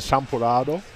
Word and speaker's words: Champurrado: I think for me, the favorite of these three is the Champurrado. Mm-hmm. Am I saying that right? – Yeah.